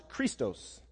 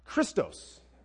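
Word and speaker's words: Christos (Χριστός): This is pronounced Christos. That's chi, rho, iota, sigma, tau, omicron, sigma. Yes, seminary. Woo! Christos. Sounds like Christ, yes.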